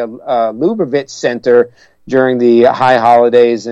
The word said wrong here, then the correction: Lubavitch: Well, actually, that's pronounced Lubavitch. The accent is on the second syllable, Lubavitch.